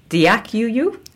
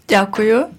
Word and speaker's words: дякую: But my understanding to say thank you is it "дякую"? – Дякую. – Дякую. – Yes.